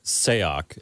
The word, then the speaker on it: Sayoc: I think it's pronounced Sayoc. It's S-A-Y-O-C.